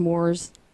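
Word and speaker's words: Moors: The Moore's, like the English Moore's. – M-O-O-R? – Yes. – Okay, are you sure that's how you say it? – That's how you say it.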